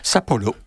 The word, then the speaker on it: Sapporo: Sapporo, pour le prononcer à la japonaise,